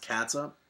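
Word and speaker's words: catsup: So it's pronounced cats up.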